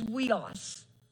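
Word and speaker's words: huios: This is weos. It's H-U-I-O-S. Now, I had to ask Alexa how to say it because all I could think of was Julio.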